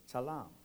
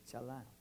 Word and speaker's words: chalam: In the Hebrew, the word dream is chalam, which is spelled chet, lamet, final, mem.